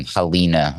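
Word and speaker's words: Halyna: Well, you pronounce her name Halina, I'm pretty sure. Halina Hutchinson.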